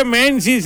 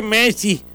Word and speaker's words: Messi: No se dice Messi, se dice Messi.